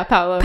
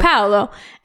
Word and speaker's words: Paolo: And Paolo, right? – Yeah, Paolo. – Paolo. And sorry, I have to say it like Lizzie McGuire.